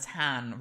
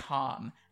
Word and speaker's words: Han: He pronounces Han as Han rather than Han. Which is how a lot of the characters in it, as in Hans but dropping the S as opposed to Han, which is how a lot of the other characters pronounce it.